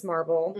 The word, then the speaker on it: MRBL: And MRBL, which you pronounce as Marble, mm-hmm.